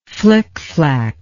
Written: flicflac

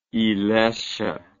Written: ilesha

